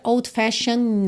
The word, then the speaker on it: old-fashioned: Porque o E não é pronunciado, certo? E o D pode ou não ser pronunciado, a depender de uma série de coisas, mas aqui, como a gente tem o N antes do E e outro N já na próxima palavra, a gente acaba juntando no N e ignora o D. Então fica old-fashioned notion, tá? But a sweet old-fashioned notion. E eu pintei esse T-I-O-N aí para você lembrar que essas terminações de palavras T-I-O-N se pronuncia shan, shan, tá?